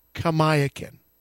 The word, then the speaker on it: Kamiakin: how was your father's name pronounced? And he said, Kamiakin.